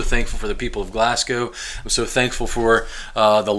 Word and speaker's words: Glasgow: But then when I was 8 years old, our family moved to southern Kentucky, south central Kentucky, a town called Glasgow, Kentucky, named after Glasgow, Scotland. But we pronounce it Glasgow because we are from Kentucky.